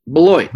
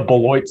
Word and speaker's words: Beloit: It's pronounced Beloit. – Beloit. – The Beloit Snappers. There we go. So I think that's like the third time that they played Beloit this year. I think this is the first time we've actually pronounced it correctly.